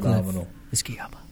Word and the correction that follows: Kayaba: akahiko kayaba thank you yeah i say it terribly don't